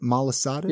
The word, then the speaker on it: Malasadas: Malasadas? – Yep.